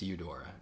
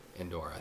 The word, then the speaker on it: Eudora: It's Eudora, not Endora. I think I'm saying Endor like I'm at Star Wars, but I think it's Eudora.